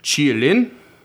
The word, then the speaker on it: Qilin: And he specified that there's certain ways to pronounce certain syllables in a lot of, especially Mandarin, I think he said. And sometimes they each syllable will take a dip. Its own dip. So I'm going to do my best, but it's supposed to be pronounced something along the lines of Qilin.